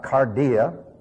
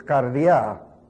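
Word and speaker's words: kardia: You notice how I pronounce it. That's the way it's pronounced, not cardia. The accent is in the wrong place when you say cardia. It's cardia.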